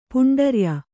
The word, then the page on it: ಪೌಂಡರ್ಯ: pauṇḍarya